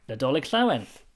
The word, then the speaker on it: Nadolig Llawen: Nadolig Llawen. – Nadolig Llawen.